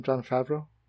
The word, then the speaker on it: Favreau: Is that how you say his name? Jon Favreau? – Yeah.